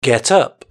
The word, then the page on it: get up: British English